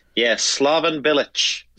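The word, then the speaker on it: Slaven Bilić: Slaven Bilic.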